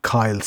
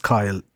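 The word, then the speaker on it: Cill: Originally, Kyle, C-A-I-L-L, and still pronounced Kyle in Munster. But Quill in other parts of the country.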